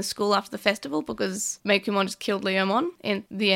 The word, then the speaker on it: Meikumon: So then when we have Daigo and Maki sort of talking about Meikumon, all I noticed is that Daigo says Meikumon really weirdly. – Yeah, Meikumon. – It's like emphasis on the ku instead of the mei. Like it's a hard ku.